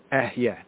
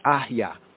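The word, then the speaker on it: Ehye (אהיה): Now some latter-day Hebrews and Israelites, they mispronounce it. They call it correct, but it's really incorrect if we study Hebrew more comprehensively, right? But it's baby Hebrew, all the same. They will say it's Ahya, Ahya.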